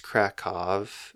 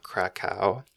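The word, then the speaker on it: Kraków: Really quickly also, it's Krakow, not Krakow. It's spelled K-R-A-K-O-W, but W's are pronounced as V's in Polish, just an FYI.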